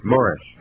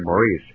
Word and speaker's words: Maurice: Yeah, now you pronounced it correctly, too. Morris. – Yeah. – Americans say Maurice.